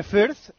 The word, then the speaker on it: Fürth: Ah, muy bien Greuther pronunciado. Fürth. – ¿Se dice así? Ah, el líder con... ¿Se dice así, Paniagua? – Creo que sí. – ¿Tiene nombre? – Deletréame el nombre, el segundo. – F-U-R-T-H. – Fuert. – Como en Cataluña, ¿no? – Para 1-0.